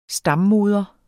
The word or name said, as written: stammoder